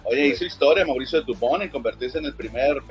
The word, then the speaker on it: Dubón: Exactamente, bien, entonces Mauricio Dubón, este jugador que pertenecía a los gigantes de San Francisco, lo recuerdo por ahí, Mauricio Dubón, con acento en la O, exacto.